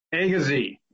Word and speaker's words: Agassiz: And I'm sorry, I've never been confident in my pronunciation of Lake Agassiz? – Agassiz. – Yeah. Oh, well, I can probably remember that.